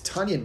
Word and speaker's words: Tonyan: It's Tonyan, by the way. Did you know that? – I did. It rhymes with onion. – Yeah, not Tonyan.